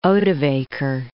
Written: Odovacar

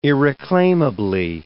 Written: irreclaimably

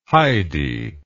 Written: Heidi

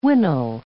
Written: wintle

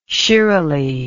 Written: shiralee

